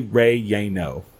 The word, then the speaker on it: relleno: But I've looked it up, and apparently the Spanish-speaking world says chili relleno. Relleno.